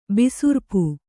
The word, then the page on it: ಬಿಸುರ್ಪು: kannada